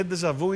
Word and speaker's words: ντεζαβού: Είναι ντεζαβού ή ντεζαβή? – Ντεζαβού. – Τώρα έξω μου ήμουν το προφέρω λίγο λάθος. – Ντεζαβού. – Ντεζαβού. Για ντεζαβού.